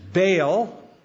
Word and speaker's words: Baal: Now, in that text, two different gods are mentioned Baal, a better pronunciation is Baal. That's how they would say it, Baal, but you can say Baal because we're Americans.